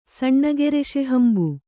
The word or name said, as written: ಸಣ್ಣಗರಸೆಹಂಬು